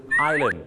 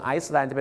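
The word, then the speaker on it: island: อย่าลืมครับคำว่าเกาะก็คือไอแลนด์ไม่ใช่ไอแลนด์หรือไอซ์แลนด์นะครับถ้าออกเสียงไอซ์แลนด์จะเป็นประเทศทางเหนือเลยนะครับเมืองน้ำแข็งนะฮะ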